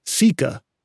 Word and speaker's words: caeca: One interesting feature of the large intestine, in some birds, is the presence of structures called ceca. That's spelled C-A-E-C-A.